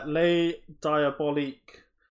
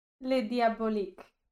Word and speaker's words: Les Diaboliques: Um, so we are watching uh, The Wages of Fear from 1953 and uh, Les Diaboliques from 1955. I've I've murdered that, haven't I? – Les Diaboliques. – There we go.